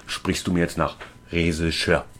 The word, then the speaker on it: Regisseur: Regisseur. – Dann versuch's doch mal so. – Das Reh scheißt irgendwo hin. Reh schiss, hör.